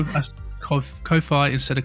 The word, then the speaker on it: Ko-fi: It's two words, K-O-F-I, separated with a hyphen. That's Ko-Fi. – Apparently it's pronounced coffee. – But it's got one F.